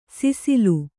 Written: ಸುಸಿಲು

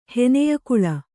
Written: ಹೆನೆಯಕುಳ

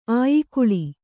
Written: ಅಯಿಗುಳ

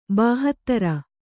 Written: ಬಾಹತ್ತರ

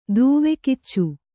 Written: ದೂವೆಕಿಚ್ಚು

